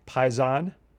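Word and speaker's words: paisan: Paizan. – Paizan with the accent on the last syllable.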